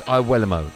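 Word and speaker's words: Iwelumo: On now to kick off with Hugh Wisdencroft and Chris Iwuelamo, and that is how you pronounce his name.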